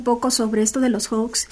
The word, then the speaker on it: hoax: Bueno, el día de ayer estaba escuchando que estaban hablando sobre estos famosos hoax, estos engaños, fraudes, ¿Cómo se llaman? – Hoax. Se escriben H-O-A-X, es una palabra inglesa.